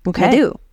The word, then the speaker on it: Nadeau: Ooh, N-A-D-E-A-U. – Okay. – Naidu.